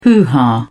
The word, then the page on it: hoo ha: British English